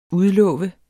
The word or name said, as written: udlove